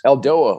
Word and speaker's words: Eldoa: Right, I say this wrong yeah. every single time. I switch it up. I'll say Eldoa, Alboa.